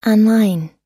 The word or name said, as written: online